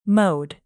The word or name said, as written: mowed